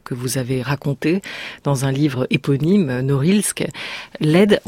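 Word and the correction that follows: Lëd: on le prononce comment alors, du coup? – Liet. – Hein, c'est ça, Liet. Alors j'ai dit Liet, c'était n'importe quoi, du coup.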